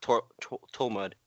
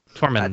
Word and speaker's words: Tormund: I thought his name was actually pronounced Tormund. – No. – Tormund. – Tormund Giantsbane. – Is there an N? I didn't know there was an N. I thought there was just M-U-D.